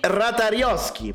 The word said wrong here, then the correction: Ratajkowski: Ma perché not- Ratajko- non è Ratajkowski? – Ratajkowski che non sono in grado di pronunciare il suo nome.